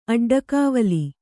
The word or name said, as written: ಅಡ್ಡಕಾವಲಿ